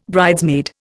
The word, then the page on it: bridesmaid: Indian